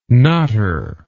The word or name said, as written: knotter